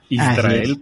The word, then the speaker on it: Israel: ¿Israel, para pronunciarlo bien? – Así es, así es.